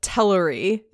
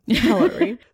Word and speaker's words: Teleri: And we sort of get into that starting in this chapter, because this is where one of the major splits of the Teleri happens. – So is it, sorry, I noticed that you're saying Teleri. Is that the pronunciation? Cool. I've been saying Teleri the whole time. Teleri.